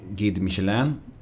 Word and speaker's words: Guide Michelin: Och de hade en restaurang eh, som hade en stjärna i eh, Guide Michelin.